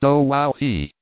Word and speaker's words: Oahu: I named him after this island, Oahu, pronounced O-Wow-He, the first gate he came to he started in.